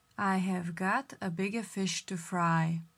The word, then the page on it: Have got bigger fish to fry: slow